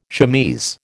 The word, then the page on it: chemise: American English